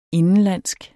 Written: indenlandsk

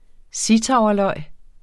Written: zittauerløg

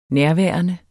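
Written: nærværende

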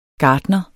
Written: gartner

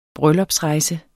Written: bryllupsrejse

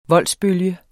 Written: voldsbølge

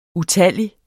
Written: utallig

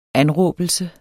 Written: anråbelse

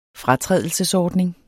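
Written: FRATRÆDELSESORDNING

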